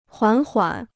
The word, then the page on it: 缓缓: huǎn huǎn